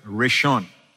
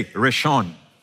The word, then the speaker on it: Rishon: first in anything is the word Rishon. Say Rishon. Rishon means first, and this is how it's spelled in Hebrew. Reading from right to left, Resh Aleph Shin Vav Nun, Rishon. When you hear the word Resh, it's R, right? Aleph, Aleph is the A, ah.